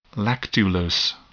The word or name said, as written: lactulose